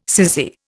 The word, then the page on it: sissy: Indian